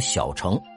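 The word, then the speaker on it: 小乘: 注意啊，这个字有人读成，有人说读圣，我认为还是应该读成小成。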